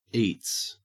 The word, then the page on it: eats: Australian English